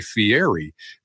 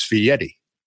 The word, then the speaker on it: Fieri: And I wanted to make sure that I pronounced your name correctly because people look at it and say Fieri, but it's Fieri. It's Fieri.